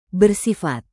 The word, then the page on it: bersifat: indonesian